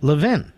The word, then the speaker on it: Levin: The truth is, it's pronounced Levin everywhere but in Philadelphia, which is where I grew up. And they pronounce it Levin.